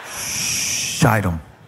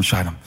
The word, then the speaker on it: Shittim: It's a long I. Very important. Shittim. Y'all are so bad. What kind of church is this? Secretly sent two spies from Shittim.